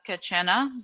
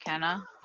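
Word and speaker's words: quena: Yeah, it's like a kachena, but it's pronounced kenna for like the English, you know, version.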